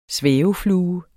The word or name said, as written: svæveflue